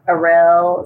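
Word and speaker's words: Aryll: And I would be so freaked out when I made it to the ship to save Aril.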